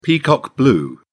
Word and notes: peacock blue: British English

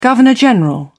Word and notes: governor general: British English

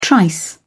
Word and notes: trice: British English

